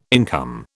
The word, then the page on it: income: British English